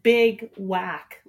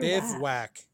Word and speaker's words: Bivouac: Bivouac, B-I-V-A-U-A-C. – Oh, I thought you said big whack, like big whack, like they're Bivouac. That's how you pronounce their name.